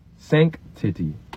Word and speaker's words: sanctity: Sanctity. Sanctity? Think sanctity. Hard C. Sanctity. Sank. And then the second half sounds like city.